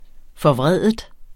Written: forvreden

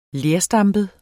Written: lerstampet

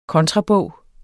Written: kontrabog

